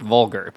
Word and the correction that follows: Goldberg: but... – Goldberg, yes.